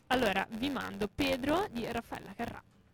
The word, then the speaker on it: Raffaella Carrà: Raffaella Carrà.